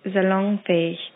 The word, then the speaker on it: salonfähig: The proper pronunciation for that word is salonfähig.